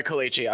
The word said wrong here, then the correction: Kalgoorlie: I have to stop you there because you're butchering this stuff. Because Kalgoorlie College... Kalgoorlie College.